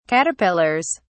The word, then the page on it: caterpillars: British English